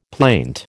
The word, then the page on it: plaint: American English